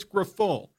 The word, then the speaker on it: Grifol: Is it Grifol? Grifol? Pedro Grifol? Like, I don't even know how to pronounce his name. Coach me up on this. – It's Grifol.